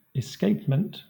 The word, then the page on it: escapement: /ɪˈskeɪpmənt/